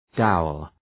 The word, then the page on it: dowel: ‘daʋəl